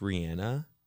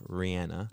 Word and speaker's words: Rihanna: Guys, did you actually know it was pronounced Rihanna? – No. – Everyone pronounces her name wrong. If you watch any interview where she introduces herself, she'll call herself Rihanna.